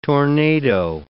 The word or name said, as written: tornado